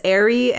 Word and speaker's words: Arye: It's A-R-Y-E, and it has two different pronunciations that I could find. One is Ari, and one is Arye. So, I don't know. Take your pick.